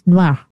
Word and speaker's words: Noir: Like, no, no, no, it's Noir.